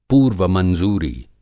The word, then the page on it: पूर्वमंज़ूरी: Hindi